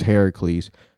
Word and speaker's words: Heracles: You just found out it's pronounced Heracles.